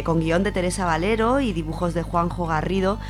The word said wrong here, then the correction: Guarnido: Guarnido, perdón, hoy siempre lo digo mal, que es conocido por hacer la serie Black Sad.